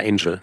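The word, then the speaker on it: Angell: Du schreibst über die vielen Verluste des Autors, heißt der Roger Agnell? – Roger Angel. Hm? – Roger Angel, ja, guck. – Ja, es ist ungewöhnlich, weil er sich mit Doppel-L schreibt, ja. Aber er spricht sich Angel.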